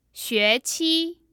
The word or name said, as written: xueqi